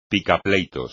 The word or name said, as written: picapleitos